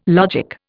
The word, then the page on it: logic: Indian